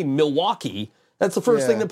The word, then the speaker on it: Milwaukee: So I'm just like, you know, yes, the Indians actually pronounced it Miliwauke, which is Algonquin for the good land.